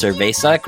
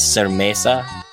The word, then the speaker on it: cerveza: It's like a 12-year-old, 13-year-old girl who sings about cerveza, but she can't even pronounce cerveza correctly. She's so young. So she says cermesa.